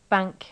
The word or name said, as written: bank